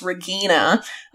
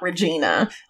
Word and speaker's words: Regina: So apparently her name is with a hard G, so it's Regina, not Regina.